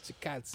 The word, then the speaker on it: Chikadze: But one of the guys that was the chickadees. – Yeah, I never know how to pronounce it, and then Chikadze. I heard the dude say it. I'm like, oh, okay, that's how you do. – Chikadze.